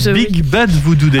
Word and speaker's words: Big Bad Voodoo Daddy: Big Bad Voodoo Daddy.